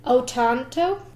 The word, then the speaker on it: Otranto: It's called The Castle of, I might be pronouncing it this wrong, but it's called The Castle of Otranto, or O-T-R-A-N-T-O. – Otranto? – Otranto. I think so, yeah. – O-T-R-A-N-T-O. – Otranto. It's as close as we can get for it.